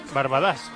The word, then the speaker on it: Barbadas: Pontevedra 1, Barbadas 1, Castellón 2, Crevillente 0. Barbadas, con acento en la A de Barbadas.